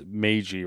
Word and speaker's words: Meiji: The only one I know for sure is it's Meiji Restoration. – It is Meiji? – Okay.